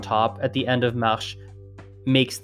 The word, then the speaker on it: marche: And why, for instance, a common one I get is why the word marche, M-A-R-C-H-E, is pronounced marche, but the word marcher, M-A-R-C-H-E with an accent aigu, is pronounced marché.